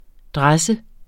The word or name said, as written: dresse